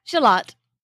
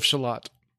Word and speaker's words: Shalott: How do you pronounce it then? – Shalott. – Like Lady of Shalott. – Lady of Shalott. It's an Arthurian story.